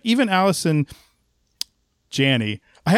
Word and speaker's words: Janney: this is how I would pronounce it because the E's there, so it looks like J-A-N, and then I'm like, oh, there's two, okay, Janney, all right, so she was good, but I remember us watching that and going, like, man, she needed her own episode.